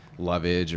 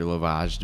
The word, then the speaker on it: lovage: He was using lovage or lovage, depending how you say it, the celery family kind of derivative.